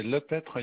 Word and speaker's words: Lepeytre: Alors, c'est Lepetre Yann, ça s'écrit L-E-P-E-Y-T-R-E.